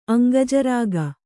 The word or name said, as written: ಅಂಗಜರಾಗ